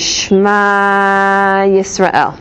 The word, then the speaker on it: Shema Yisrael: When we say the Shema, the way it's pronounced is Shema Yisrael. Adonai Eloheinu Adonai Echad. Right, so you say the d harder, because the ayin and the dalad together make the witness.